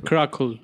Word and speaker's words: Crackle: Eh, fue emitida en el 2012 a través de su web eh, y luego ¿Cómo se pronuncia Cracker? – Crackle.